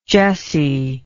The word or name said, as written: jessie